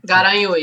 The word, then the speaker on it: Garanhuns: Cheiro para Carlos Moreira, para Dani de Guaranhuns, em Pernambuco. Falei certo? É Guaranhuns? É, né? – Garanhuns. Garanhuns. Garanhuns.